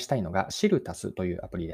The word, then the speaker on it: SIRU+: 大文字で SIRU と書いて、足すというのは足し算のあのプラスの記号ですね。これでシルタスと読みます。